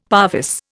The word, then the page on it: parvis: Indian